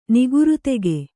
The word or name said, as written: ನಿಗುರುತೆಗೆ